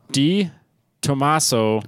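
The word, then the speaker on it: De Tomaso: It is a Di Tommaso.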